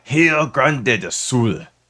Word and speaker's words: Rio Grande do Sul: And Rio Grande do Sul. Did I say that correctly? – Yeah. – R's are pronounced like H's, if you didn't know.